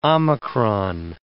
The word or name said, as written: omicron